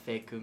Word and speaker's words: Faeku: Mine's called Feku Music. – Can you spell that for our listeners? – It's spelled F-A-E-K-U, and then music afterwards.